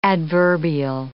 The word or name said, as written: adverbial